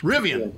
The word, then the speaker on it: Rivian: And there's also a pickup truck by a startup group called, is it pronounced Revan, or Revan? I always get confused. Sorry Rivian. – About that. Rivian. Thank you.